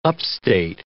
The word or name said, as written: upstate